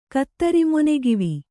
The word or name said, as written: ಕತ್ತರಿಮೊನೆಗಿವಿ